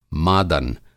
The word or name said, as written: मादन